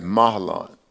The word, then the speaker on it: Mahlon: I pronounce that Mahlon, and Chilion.